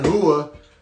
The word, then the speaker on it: Rua: but I've watched enough UFC to know that the Brazilians pronounce their R's with a H sound. So it's Mauricio Shogun Rua, +150 underdog, versus Paul Craig, -170 favorite.